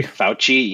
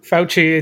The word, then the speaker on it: Fauci: Fauci, yeah, that's what I thought. – Fauci, it's uh, I think the C's in Italian are pronounced as CHs.